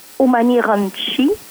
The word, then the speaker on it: Umani Ronchi: Puis on prononce Umani Ronchi, mais le CH se prononce comme un K en italien.